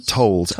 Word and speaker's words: Amor Towles: Amor Towles. – That's right. Towles.